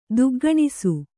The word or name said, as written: ದುಗ್ಗಣಿಸು